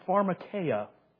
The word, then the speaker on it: pharmakeia: The word here is, in the Greek, pharmakeia. Pharmakeia. That sounds like the word what? Pharmacy.